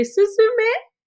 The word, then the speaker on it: Suzume: I mean, I'm going to say it like you'll hear it in the film. When there's the cutest cat in the world in this beautiful Japanese anime, and the cat will say, Suzume, Suzume. And it will ring in your ears after seeing this absolutely stunning animation.